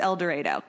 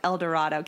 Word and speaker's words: El Dorado: I'm pretty sure it's El Dorado because I would have pronounced it El Dorado, Kansas, but it's apparently pronounced El Dorado.